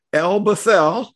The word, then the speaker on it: El Bethel: 2002, you and Denice moved near Coloma, where you became pastor of your church, which is, if I hope I'm going to pronounce it correctly, El Bethel. – Yes. – Many of us would say Bethel, but we want to be accurate here.